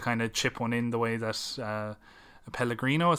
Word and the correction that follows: Pellegrini: is that how you say his name? – Pellegrini.